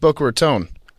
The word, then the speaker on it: Boca Raton: Fun fact, it's pronounced Boca Raton.